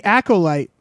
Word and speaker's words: Acolyte: The Acolyte.